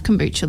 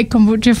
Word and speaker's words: kombucha: You also say kombucha funny. – Oh, do I? – Yeah. – Well, how did you say it? How do I say it? – Kombucha. – Kombucha. – Oh. – How do you say it? – Kombucha.